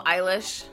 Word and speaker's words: Eilish: Tariq Nasheed kept saying Billy English. – Oh, Eilish? – Yeah.